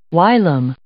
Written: whilom

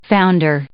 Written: founder

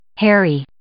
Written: harry

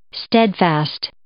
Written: steadfast

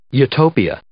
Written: utopia

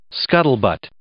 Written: scuttlebutt

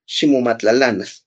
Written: ximomatlalanas

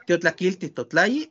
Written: tiotlakilti totlayi

